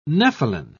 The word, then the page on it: nepheline: British English